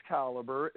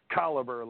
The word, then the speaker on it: Caliber: No, it is Caliber. It is a very much made-up name for the stage and screen. Oh. So it's Caliber, like a high-caliber rifle.